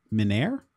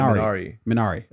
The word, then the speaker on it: Minari: Although I am gonna wonder if I'm pronouncing this right, but I believe it's pronounced Minari. – See, I read you that Minari.